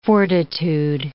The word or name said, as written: fortitude